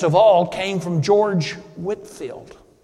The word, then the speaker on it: Whitefield: It's pronounced Whitfield, it's spelled Whitefield, a great British preacher from long ago.